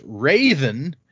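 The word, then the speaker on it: Hrathen: Oh, Rothen. – According to this, that is pronounced Raven with a very subtle H at the beginning.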